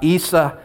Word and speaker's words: Isa: And he began to talk to us about Isa, and that's how you say it in Indonesian and in Arabic, Jesus.